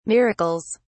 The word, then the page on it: miracles: British English